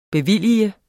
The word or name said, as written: bevilge